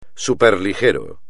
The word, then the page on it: superligero: espagnol